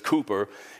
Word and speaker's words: Cowper: I love what the English poet and hymn writer, his last name is pronounced Cooper. It looks like Cowper, but it's Cooper.